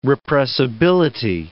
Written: repressibility

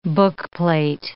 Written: bookplate

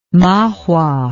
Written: mahua

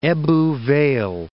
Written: Ebbw Vale

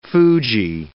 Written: fuji

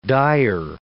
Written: dyer

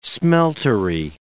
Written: smeltery

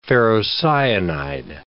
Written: ferrocyanide

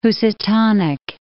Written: Housatonic

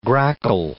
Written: grackle

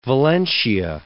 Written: Valencia

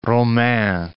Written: Romains

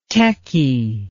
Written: tekkie

